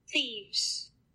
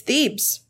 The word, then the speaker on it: Thebes: Thebes. – Yep. Okay, it's Thebes.